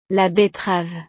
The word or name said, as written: la betterave